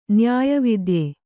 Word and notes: ನ್ಯಾಯವಿದ್ಯೆ: nyāya vidye

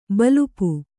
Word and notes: ಬಲ್ಪು: balpu

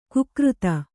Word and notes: ಕುಕೃತ: kikřta

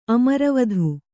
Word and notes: ಅಮರಾವತಿ: amarāvati

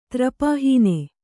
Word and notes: ತ್ರಪಾಹೀನ: trapāhīna

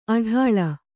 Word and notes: ಅಟ್ಟಾಳ: aṭṭāḷa

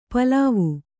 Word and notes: ಪೇಲವ: pēlava

